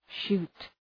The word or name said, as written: shoot